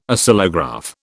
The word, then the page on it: Oscillograph: British English